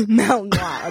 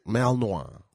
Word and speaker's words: Malinois: Malinois? How do you say that? – Malinois. A Belgian Malinois. – Malinois. – Yeah. – Malinois. Like Pinot Noir.